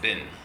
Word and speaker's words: Bin: In Gantz Chumash, in Gantz Tanakh, Yeshua Bin Nun's name is pronounced Bin, with a chirik under the base.